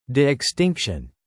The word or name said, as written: de-extinction